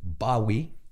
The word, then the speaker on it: Bowie: Jim Bowie, mejor conocido como se pronuncia por los ingleses Bowie, de donde tomó su nombre David Bowie.